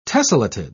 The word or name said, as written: tessellated